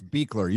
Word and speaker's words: Buechler: Hello, Mr. Gary, I'm going to say it wrong, Beckler, yeah? – It's Beekler. You were close.